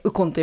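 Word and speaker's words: 鬱金: ウコンは日本語です。 – はあ、じゃあ完全なイコールで考えていいんですか。はい、イコールです。うつに金と書いてウコンと読みます。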